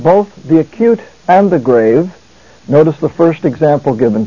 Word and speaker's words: grave: In some language classes, this is pronounced the grave. But in Greek, it is the grave.